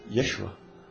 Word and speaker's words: Yeshua: de Yeshua, não sei como se pronuncia é, em aramaico ou em hebraico. É, Yeshua. É.